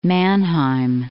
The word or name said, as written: Mannheim